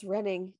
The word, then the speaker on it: Reading: Now, during the construction of, I'm looking at it, and it says Reading Road, and I'm pretty sure it's pronounced Reading, but I'm not quite sure, so we're going to go with Reading.